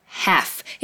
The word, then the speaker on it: half: First is the pronunciation of half. It's not half, but half. Repeat, half.